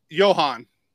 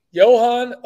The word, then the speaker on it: Johan: Is it pronounced Jovan? Johan? Johan? – Johan. – Johan Oviedo with Pitt under 6.5 Ks at plus 110.